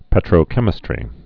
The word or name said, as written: petrochemistries